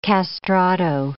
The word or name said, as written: castrato